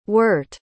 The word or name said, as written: wurt